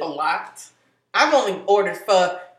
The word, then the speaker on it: pho: But that's like, you be eating pho a lot. Or pho.